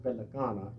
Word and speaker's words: Pelicana: And incidentally, that word Pelicana is a corruption of the Spanish word Americano, American. Mm-hmm. The Navajos can't pronounce the letter R. And the nearest they can come to it is L. So it's Pelicana is American.